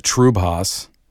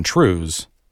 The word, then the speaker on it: triubhas: And then another word, another spelling, T-R-I-U-B-H-A-S. – Oh. – I saw the pronunciation. I looked it up. There was a video that pronounced it Trubhas, but there is what I got into, a Scottish dance called the Shan Trues. Word spelled T-R-I-U-B-H-A-S, pronounced trues. – Jeez Louise. – So it is pronounced trues. I looked it up. I made sure.